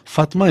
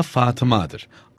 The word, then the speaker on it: Fatıma: Fatma ismi aslında Fatıma'dır.